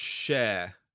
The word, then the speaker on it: Schär: It's pronounced Schär, just for punditry.